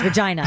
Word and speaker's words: Regina: Well, I was saying, that's how you say it, it's Regina.